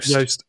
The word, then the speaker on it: Yoast: I don't know if I've said, have I pronounced that right? Juiced, or is it Yoast? – Yoast. Oh, okay. So Yoast is the pronunciation.